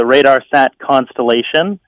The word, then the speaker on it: RADARSAT Constellation: Yep, so you've got it right. So it's the RadarSat Constellation, and you might see that abbreviated as RSC sometimes, or the RCM, okay. – RadarSat Constellation Mission.